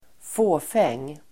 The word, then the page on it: fåfäng: ²f'å:feng: